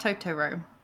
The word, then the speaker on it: Totoro: You know, it is actually pronounced Totoro. – Yeah. I can't say it like that, though. – But yeah, we've forever just been calling it Totoro. So. – It's on the same level as pedo. – Yeah, we just can't pronounce it like the proper Japanese way.